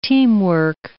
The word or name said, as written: teamwork